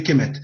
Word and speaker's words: Kemet: Ça s'écrit KMT, mais ils l'appelaient Kemet.